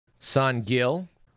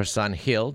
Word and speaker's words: San Gil: And on our second day of doing this, we found ourselves in a wonderful little colonial town high up in the Andes, San Gil, or San Gil, depending if you want to have a more authentic pronunciación.